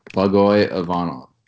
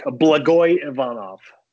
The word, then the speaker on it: Blagoy Ivanov: Blagoy Ivanov. I have a little bit of expertise as to how to pronounce Russian names, if nothing else, because I have a Russian heritage and speak the language. So I'm gonna say that with authority, Nick. Blagoy Ivanov.